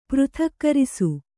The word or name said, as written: ಪೃಥಕ್ಕರಿಸು